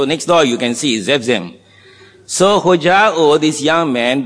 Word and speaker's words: Zephzem: M-I-N-A-H. J-O-M-I-N-A-H. So you got Hojao, Jomina, and the last one, the third name is Zephzem. Z-E-P-H. Z-E-P-H, the P-H you can pronounce as F, Zephzem. Zephzem. Z-E-P-H, continuation, Z-E-M.